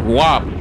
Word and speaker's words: WAP: I mean, you a rhymer. WAP. Top. Bop. It's like a O and a P.